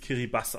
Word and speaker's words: Kiribati: Auf Kiribatisch wird es Kiribas ausgesprochen.